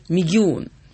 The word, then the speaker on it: Мегион: Мальчик, между прочим, из-под Ханты-Мансийска, вот, города Мегион, если я правильно ставлю ударение. – Правильно, абсолютно.